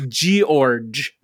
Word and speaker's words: George: If my name had a G-O in front of it, then yes, you could call me that. But it's George.